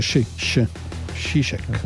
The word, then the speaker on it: Žižek: Allora, visto che parrebbe in realtà Žižek, va bene. Io avevo un amico, sloveno, che è scritto in lo stesso modo, questa Z con sopra un cupulicchio, che si legge Žižek.